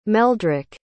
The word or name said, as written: meldrik